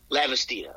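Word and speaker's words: Lavastida: How do you pronounce his last name? Is it Lavastina? – Lavastina. Okay, yeah.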